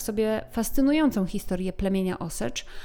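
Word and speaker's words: Osage: Jeszcze jedna uwaga, prawidłowa wymowa tego plemienia to Osycz, natomiast ja prawdopodobnie mm, tu i ówdzie będę dla wygody mówiła Osagów, plemię Osagów. Natomiast nie miejcie mi tego za złe, jest to wyłącznie dla mojej wygody.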